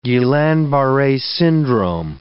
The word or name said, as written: Guillain-Barre syndrome